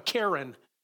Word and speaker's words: cairn: C-A-I-R-N, a cairn. Cairn is a pile of rocks. I always call them a cairn. It's not a cairn, it's cairn.